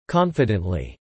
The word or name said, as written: confidently